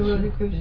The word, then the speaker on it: Rosicrucian: How do you say that? – Rosicrucian? – The Rosicrucian.